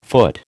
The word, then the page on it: foot: American English